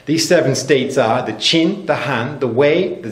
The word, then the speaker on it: Qin: In English, it's spelled Q-I-N, but it's pronounced Qin.